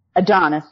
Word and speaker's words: Adonis: So while she's working at this paper, she meets Adonis. I believe that's how you say it. We'll get to him. – Adonis, there you go.